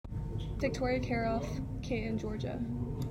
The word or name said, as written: Victoria Kjaerulff